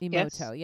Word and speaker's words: Masaru Emoto: And I was in a phase with Masaru Emoto. Is that my saying his name right? – Correct. – Emoto, yes.